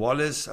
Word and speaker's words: Wallace: Bei Sky wird er jetzt immer Wallacy genannt von den Moderatoren. Jens Todt, unser Sportchef, nennt ihn Wallace. Also der müsste es ja eigentlich wissen.